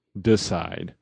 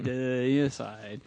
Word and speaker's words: Deicide: So anyway, I want to talk about Deicide, which I don't know how many times will get mispronounced as Decide. Right? – Deicide.